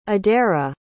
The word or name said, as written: adaira